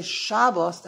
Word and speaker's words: Shabbos: So when you say Shabbos, you're talking Yiddish. In Yiddish, there's no such thing as putting an emphasis on the last syllable of the word. In Yiddish, the rules of Yiddish are every single word, the emphasis is on the beginning of the word. So you say Shabbos, that's correct.